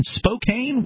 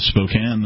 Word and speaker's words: Spokane: I want to say welcome to KXLY AM 920 and FM 100.7 in Spokane, Washington.